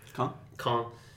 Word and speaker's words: Caen: He beat, how do you say, Cayenne or whatever? – Caen.